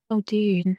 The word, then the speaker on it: Muldoon: And for example, think about the velociraptors and Robert Muldoon. Muldoon. How do you say that? – Muldoon.